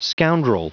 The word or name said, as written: scoundrel